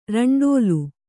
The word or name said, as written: ರಂಡೋಲು